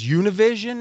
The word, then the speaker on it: Univision: So I think it was Univision is the way you pronounce it, ended up buying all the assets of the company right.